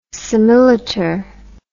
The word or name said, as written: similiter